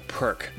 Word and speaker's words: Perq: And they're the co-founders of a marketing tech company called Perk. That's spelled P-E-R-Q.